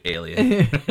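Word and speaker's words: Aelian: It's spelled A-E-L-I-E-N, but like it really is basically Alien.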